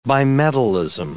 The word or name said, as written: bimetallism